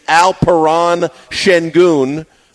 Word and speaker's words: Alperen Şengün: The correct pronunciation, Alperen Şengün.